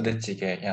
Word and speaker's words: 부대찌개: How do you say it? – 부대찌개, yeah.